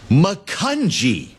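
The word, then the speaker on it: Macungie: Makunji. It's a soft G.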